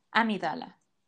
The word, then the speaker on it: Amidala: Okay, it's really similar. It's Padme. – Padme. – Amidala.